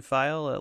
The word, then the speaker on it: Pfeil: It's just at Ryan File. Last name is spelled P-F-E-I-L. It's German for arrow, if that...